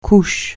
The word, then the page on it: couche: francais